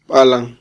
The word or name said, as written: balan